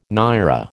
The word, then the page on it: naira: American English